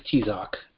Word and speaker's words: Tzok: Well, it's phonetic for English, so it's the Americanized pronunciation of an Aztec name. In Spanish, it's Tzok, but I figure Tzok is easier, so that's how I'm saying it.